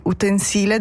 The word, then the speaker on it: utensile: Allora, se si usa come sostantivo, come in questo caso, l'accento va sulla I, utensile da cucina.